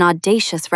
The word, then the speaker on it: audacious: Note, it can describe brilliant bravery, like an audacious rescue, or brazen nerve, like an audacious remark. Stress the middle syllable.